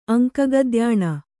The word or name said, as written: ಅಂಕಗದ್ಯಾಣ